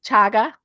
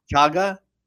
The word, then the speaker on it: Chaga: So that's Maitake activating those frontline defenders, and then um, I think I'm saying this right. Shaga. – Chaga. – Chaga. Shaga sounds like a dance.